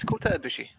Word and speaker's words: Ibushi: His name is Kota Ibushi.